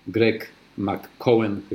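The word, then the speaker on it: Greg McKeown: Więc mniej, ale, lepiej esencjalista Greg McCohen, chyba on się tak wymawia.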